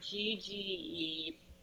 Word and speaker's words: hygge: So, I think it's pronounced Hygge. It's Hygge.